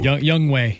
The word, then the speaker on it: Young Ho: Young Wei. That's how it's pronounced. – Oh, that's pronounced Wei. – Young Wei Koo. – Yeah, it's spelled Ho, though. – That's it is. – Young Ho.